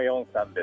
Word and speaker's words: 龍山: 龍の山と書いて四山と読むところですね。 – そうです、あの四山です。